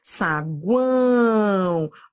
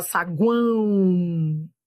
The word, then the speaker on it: saguão: Saguão, ó, semivogal, vogal, semivogal. Aproveite esse vídeo de teoria, entenda os detalhes. Tá vendo esse O aqui? Ó, ele é semivogal, ele tem som de U, ó, saguão.